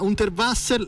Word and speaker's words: Unterwasser: Si parla di Maze per la compagnia Unterwasser. La mia pronuncia, prendila per come viene, ci aiuti a capire che cosa succede sul palco. – Invece la tua pronuncia è perfetta.